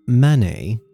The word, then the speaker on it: Manet: And no, I did not pronounce that wrong. This is Manet, which some confuse for Claude Monet, who was the super famous impressionist painter from the same era.